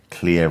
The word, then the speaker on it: Clea: But again, yeah, having Clea back, I'll remind me now, Clea rhymes with here, right? – I know.